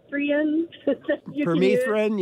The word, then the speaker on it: Permethrin: So I know I'm not saying this right, that Permethrin. – Permethrin, yeah.